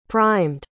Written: primed

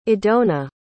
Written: idona

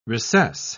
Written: recess

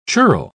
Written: churl

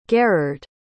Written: garrard

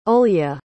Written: Olya

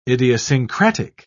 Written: idiosyncratic

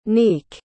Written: nneke

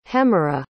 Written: hemera

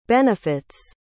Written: benefit's

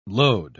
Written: lode

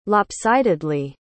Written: lopsidedly

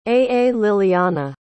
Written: Aalilyanna

